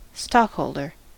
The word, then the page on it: stockholder: American English